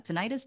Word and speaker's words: tinnitus: How do you say that? Tinnitus, tinnitus? – Titties.